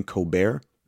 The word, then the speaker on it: Colbert: But she also was herself and a performer on a late show with Stephen Colbert. Remember that, T is silent.